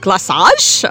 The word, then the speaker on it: glaçage: How do you say that? It's not ganache. Something else. – Glaçage? – Glaçage? Okay.